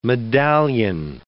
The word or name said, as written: medallion